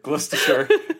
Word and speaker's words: Gloucestershire: It's probably like Gloucestershire or something. – It's like Gloucestershire. Yeah. Like Worcestershire. – Yeah. – It probably is Gloucestershire.